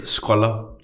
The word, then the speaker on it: scroller: Øh, jeg synes, og det udtales, scroller.